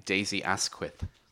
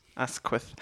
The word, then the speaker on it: Daisy Asquith: Dizzy Athquist. It's kind of... How do you pronounce her surname? It's not great for people with lisps. – Oh, Daisy Asquith. – Asquith.